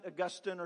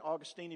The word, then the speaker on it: Augustine: I like St. Augustine or St. Augustine, if you prefer to pronounce it that way, it's fine.